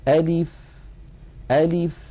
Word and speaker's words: ألف: زمن الفتحة في أ مساو لزمن الكسرة في لي مساو لزمن البقية الحركات، ألف ألف هكذا من غير تكلف.